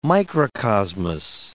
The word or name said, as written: microcosmos